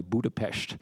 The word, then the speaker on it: Budapest: I said that right, by the way, Budapest. That's my Hungarian pronunciation.